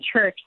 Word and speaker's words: Church: Yeah, it is whenever I tell someone my name and they're going to spell it, I always say Logan Church like Sunday.